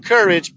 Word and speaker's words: CURYJ: So come check out our website at Courage, C-U-R-Y-J. So we pronounce it Courage, but it's C-U-R-Y-J, not Curry J, y'all. Get it right.